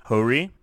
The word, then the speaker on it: Hori: Wait, how did you pronounce that? – Hori. H-O-R-I. – I always thought it was Hori.